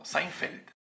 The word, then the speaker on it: Seinfeld: No, Seinfeld. – Seinfeld. – Sí, chequenlo. Búscalo ahorita mismo y vas a ver, no hay ninguna I en la segunda sílaba.